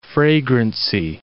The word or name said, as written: fragrancy